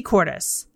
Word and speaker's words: Kortes: Just search Shop Talk with Misty Cortes, and my last name is spelled K-O-R-T-E-S, and request to join.